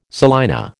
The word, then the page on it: salina: American English